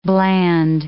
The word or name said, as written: bland